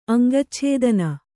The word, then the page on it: ಅಂಗಚ್ಛೇದನ: aŋgacchēdana; Kannada